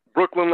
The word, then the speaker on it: Breukelen: Breukelen like the bridge in the borough, man.